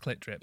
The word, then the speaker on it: CLT DRP: Which is just you, I assume, saying it's pronounced Clit Drip.